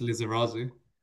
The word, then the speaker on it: Lizarazu: Apologies if I said that wrong. – Think it's pronounced Lizarazu. – Lizarazu.